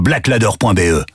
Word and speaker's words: Blaklader: Va vite voir sur blackladder.be. Black Ladder, ça s'écrit BLAKLADER.